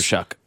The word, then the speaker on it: Shough: Shuck is how you pronounce Shuck. That name? S-H-U-C-K. Shuck. – Yes. – It looks like slow or something like that. S-H-L-O-U. – It's spelled S-H-O-U-G-H. Show. – Yeah, it's spelled. It's not spelled the way it sounds.